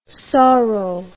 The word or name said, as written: sauro